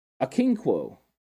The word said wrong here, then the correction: Okonkwo: I believe I'm trying to say his name right. – Chigozium Okonkwo? – Okonkwo. – Out of Maryland? – There we go.